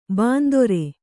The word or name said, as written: ಬಿಂದಾರಿ